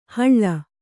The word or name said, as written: ಹಳ್ಳ